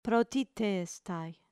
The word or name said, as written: protistestai